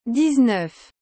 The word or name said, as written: dix-neuf